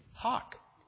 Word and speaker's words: hough: Now, for H-O-C-K, the pronunciation is H-O-K, hawk. If you look at 1828 Webster's and look up H-O-U-G-H, right after that is the pronunciation hawk. That is hawk.